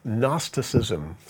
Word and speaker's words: Gnosticism: And then another religion began to spring up around the time of the New Testament called Gnosticism, spelled with a G, Gnosticism. And it was another religion, sort of a secret knowledge religion. The Greek word gnosis means knowledge, and so that's the root word of Gnosticism. We just don't pronounce the G in English anymore.